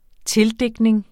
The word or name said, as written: tildigtning